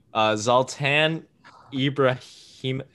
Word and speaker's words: Zlatan Ibrahimović: Zlatan Ibrahimović.